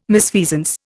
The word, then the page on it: misfeasance: Indian